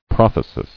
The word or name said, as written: Prothetic